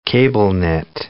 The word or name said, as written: cable-knit